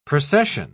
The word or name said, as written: procession